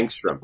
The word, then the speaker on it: angstrom: And how do you pronounce it? Engram? – Angstrom. – Yeah. Angstrom. So, angstrom.